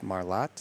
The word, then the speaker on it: Marlatt: That next guest is Dr. Jeff Marlatt. Is that how he pronounces it? – Yes.